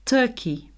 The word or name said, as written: turkey